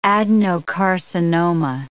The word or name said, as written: adenocarcinoma